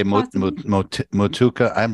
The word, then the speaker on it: Motueka: How do you say that? Motueka hops? – Yeah, I usually just say Motueka.